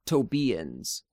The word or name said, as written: Tobians